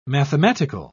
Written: mathematical

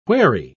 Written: wherry

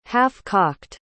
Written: half-cocked

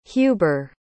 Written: huber

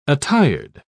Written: attired

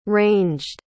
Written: ranged

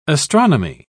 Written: astronomy

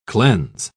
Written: cleanse

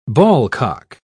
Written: ballcock

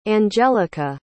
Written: Anjelica

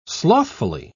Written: slothfully

